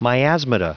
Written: miasmata

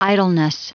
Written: idleness

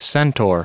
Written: centaur

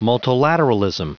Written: multilateralism

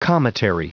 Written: cometary

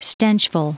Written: stenchful

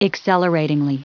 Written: acceleratingly

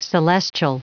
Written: celestial

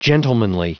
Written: gentlemanly

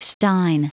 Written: stein

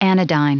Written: anodyne

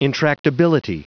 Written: intractability

